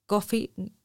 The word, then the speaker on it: Ko-fi: También lo podéis hacer a través de la plataforma de Coffee que se supone que es invitarnos a, un café. Se escribe KO-FI y nosotros prometemos hacer buen uso de,